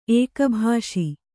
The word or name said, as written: ಏಕಭಾಷಿ